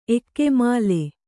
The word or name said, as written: ಏಕಮೂಲ